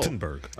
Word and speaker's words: Gutenberg: Gutenberg. Um, nice did I say that right? Yeah, I think okay.